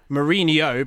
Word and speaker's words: Mourinho: Mourinho, because that's the NH, whereas Firmino, because there's no H after the N.